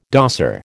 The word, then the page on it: dosser: American English